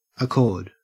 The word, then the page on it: accord: Australian English